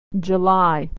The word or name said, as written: July